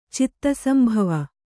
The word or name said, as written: ಚಿತ್ತಸಂಭವ